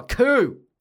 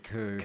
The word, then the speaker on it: coup: I'm going to throw a coup. I'm going to throw a freaking coup. I just wanted to say coup. – Coup. It's a beautiful word, isn't it? – Coup. – Cool. – P is silent.